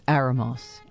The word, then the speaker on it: Aromas: It's spelled Aromas, but he says it's Aramos.